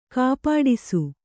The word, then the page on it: ಕಪಟಿಸು: Kannada